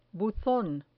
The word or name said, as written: buzon